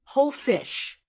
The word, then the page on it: whole fish: American English